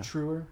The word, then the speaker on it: Treuer: referenced, uh, David True Treuer, Treuer, how, how do you say his name? – Yeah. Treuer. Treuer is this Treuer? Mm-hmm. Okay.